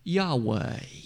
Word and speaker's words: Yahweh: Put an A in the first syllable and an E in the second one. Yahweh. You'll notice some write it with an additional A in the middle of the word.